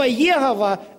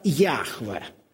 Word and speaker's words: Яхве: Но слово Егова – это такого слова не существует. Если уже говорить правильно, то этот тетраграмматон произносится как Яхве.